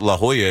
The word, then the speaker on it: La Jolla: I didn't know La Jolla out in California was, you know, pronounced La Jolla.